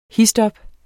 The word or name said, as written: histop